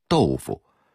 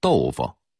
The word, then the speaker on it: 豆腐: 比如豆腐这个词，普通话应该念成豆腐，但北京人谁也不这么说，一定得说成豆腐。